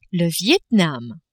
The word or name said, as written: Viet-Nam